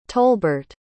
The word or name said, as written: tolbert